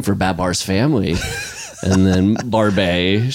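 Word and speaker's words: Barbet: I also learned how to pronounce is Barbe.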